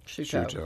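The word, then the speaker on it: Chouteau: I was listening to St. Louis on the Air yesterday, and Don Marsh's guest was Jim Kircher, and they were talking about the real pronunciation is, I think they were saying Choteau. – Choteau.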